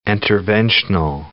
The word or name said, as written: interventional